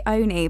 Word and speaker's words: Olney: So I say Oni, but lots of people say Olney because it's got an L.